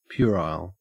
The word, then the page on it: puerile: Australian English